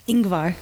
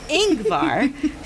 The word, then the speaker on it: Ingvar: When men sat there a-drinking, he went into the room, but when Ingvar? – Is that how you say it? How do you say it? – Ingvar. – Ingvar? – Ingvar? Okay, so the Y is silent.